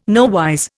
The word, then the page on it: nowise: Indian